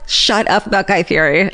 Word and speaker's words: Fieri: It's the correct pronunciation of Guy Fieri's name, which is Fieri.